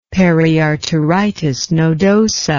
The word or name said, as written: periarteritis nodosa